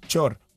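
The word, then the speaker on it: Thior: Y el apellido que se escribe Tior es Chor. Por eso el de Timbo sí es Chombo. Uh-huh. Básicamente. ¿Por qué? – Claro. – Porque la THI se convierte en una CHE.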